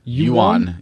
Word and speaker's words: yuan: Yuan is how the Chinese currency is pronounced, yuan. – Yuan? I thought it was yuan.